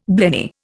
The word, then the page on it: blenny: Indian